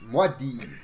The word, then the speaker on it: Muad'Dib: If you're going to say his name wrong, say it wrong right. – Muad'Dib. And you're just saying Muad'Dib. – Yeah, it's Muad'Dib.